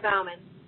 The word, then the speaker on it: Bauman: And Tristia, I hope I'm pronouncing your name correctly. – That's right. It's Tristia Bauman.